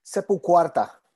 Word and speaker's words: Sepultura: Is it pronounced Sepulquarta? – Sepulquarta. – Quarta. Okay. Yeah, that's my Australian.